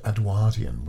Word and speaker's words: Edwardian: Edwardian. – Edwardian? – That's the way history professors say it. – Really? – I would say Edwardian myself. But I've said Edwardian, which is the correct pronunciation, apparently.